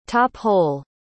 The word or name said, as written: top-hole